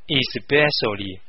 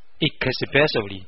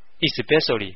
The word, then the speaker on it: especially: 注意啊，要读成 especially，不要读成 especially。在美国当中，的确有一小部分美国人也会读成 especially，这是错的，他们呢都是文化水平比较低的， especially 才是正确的。